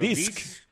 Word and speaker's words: Disc: No, Dix no, D-I-X no es, es D-I-S-C. Claro, disc. – Claro, de disco.